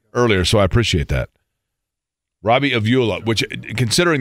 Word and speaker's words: Robbie Avila: And Jan pointed out to me that it's Robbie Avila. Robbie Avila is how you say it for Indiana State.